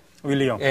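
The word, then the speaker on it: Guglielmo: 여기 기올레모라는 윌리엄이죠. 우리식으로 발음하면. 윌리엄. 예, 윌리엄이라는 사람이 이제 그 주인공이에요.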